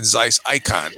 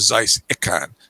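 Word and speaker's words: Zeiss Ikon: But the thing is that I'm assuming, I've always thought that it's always been Zeiss Icon, okay? As opposed to Zeiss Icon. So if it is Zeiss Icon is the accepted pronunciation for that name, then Nikon would sound just like it, which caused the lawsuit in Germany, which wasn't settled until the mid-60s.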